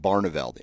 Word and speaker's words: Barneveld: Barneveld.